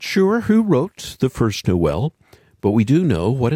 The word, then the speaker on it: Noel: The old Latin word natalis means birth, and Noel is the French pronunciation that we have inherited in the English-speaking world.